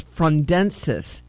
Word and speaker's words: frondescence: Today's dirty word of the day is frondensis. Frondensis, and I'm going to spell it for you. F-R-O-N-D-E-S-C-E-N-C-E.